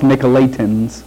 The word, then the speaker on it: Nicolaitans: Okay, the Nicolaitans, sometimes I say it either way, Nicolaitans or Nicolaitans, it's pronounced Nicolaitans.